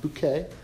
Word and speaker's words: Bucket: It's about Hyacinth Bouquet, spelled B-U-C-K-E-T, pronounced Bouquet.